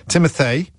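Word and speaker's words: Timothée: Timothy, that's how you pronounce his first name, by the way.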